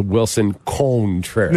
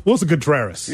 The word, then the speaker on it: Wilson Contreras: Shout out to Wilson Contreras. – No. Wilson Contreras.